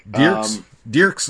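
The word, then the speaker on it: Dierkes: Dierks, I'm not entirely sure you pronounce that last name. – Dierks, what would you say? Dierks, okay.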